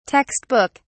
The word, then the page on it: (text) book: British English